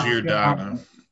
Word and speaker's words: Giordano: I am picking from the Calgary Flames, Mr. Mark Giordano. Is that how you say his name? Mark Giordano. – Giordano. Thank you.